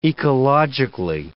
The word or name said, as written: ecologically